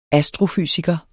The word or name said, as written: astrofysiker